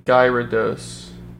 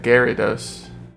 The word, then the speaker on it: Gyarados: Badger says he used to say Gyarados. Oh. I always call them Gyarados. – I would say Gyarados. – I said Gyarados. – I would like pronounce the Y, Gyarados.